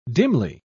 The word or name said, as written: dimly